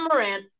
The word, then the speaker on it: Morant: Joe Moron. Mayron? How do you say it? – For John Morant. – John Morant. Yeah.